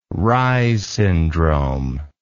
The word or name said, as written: reyes syndrome